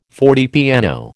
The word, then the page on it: fortepiano: American English